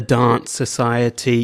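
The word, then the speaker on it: Danse Society: And then we're going to move into another one of my favorite post-punk gothy sounding bands, and it's the Dance Society. Yes, pronounced, spelt to the pronunciation of how Adelaideans say the word. It's literally D-A-N-S-E, so you're meant to say dance.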